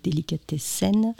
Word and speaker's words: Delicatessen: Alors, je vais le prononcer à la française, même si j'ai fait de l'allemand, évidemment. Donc, Delicatessen.